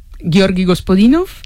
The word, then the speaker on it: Georgi Gospodinov: A ver, Georgi Gospodinov, ¿sí? – Zdravka Eftimova y Vera Mutavcheva.